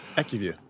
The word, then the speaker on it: AccuView: So before listeners all jump on me, I don't know how you say AccuView. How do you say that part? – AccuView. – Absolutely, you're brilliant. It's AccuView.